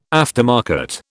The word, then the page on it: aftermarket: British English